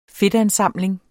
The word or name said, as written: fedtansamling